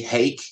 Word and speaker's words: Haak: H-A-A-K, Hake is how he pronounced it.